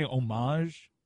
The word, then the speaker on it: homage: You say homage? – That'll work. Homage? – Homage. – The H is not silent, is all I'm saying, and I still don't know how to pronounce it.